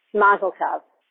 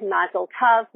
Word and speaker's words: mazel tov: Okay, that's the ah sound for the O, which rhymes with love. And that is the way. So the Mazel Tov, which Mayim Bialik, that rhymes with lava, said was unusual.